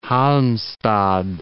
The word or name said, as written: Halmstad